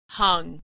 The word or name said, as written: hung